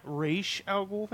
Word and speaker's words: Ra's al Ghul: Like, you know, his grandfather, who's Ra's al Ghul. – Yeah, Ra's al Ghul, thank you.